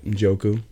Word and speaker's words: Njoku: James Conner, Jerry Jeudy, Njoku. Is that how you say it? Njoku. – Yep.